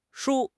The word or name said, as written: shu1